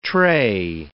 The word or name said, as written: trey